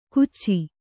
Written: ಕುಚ್ಚಿ

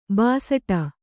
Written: ಭಿಸ್ಸಟೆ

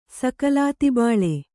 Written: ಸಕಲಾತಿಬಾಳೆ

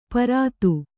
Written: ಪಾರುಧಿ

